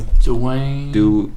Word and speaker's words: Duane: Or Duane. – Dwayne. – It's D-U-A-N-E.